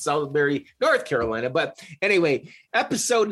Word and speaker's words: Salisbury: I'm Jerry Bonkowski with my good old buddy Ben White down there in, I'm going to say it right today, Salisbury, there you go.